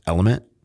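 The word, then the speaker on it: LMNT: The first one is Element, right? Pronounced Element.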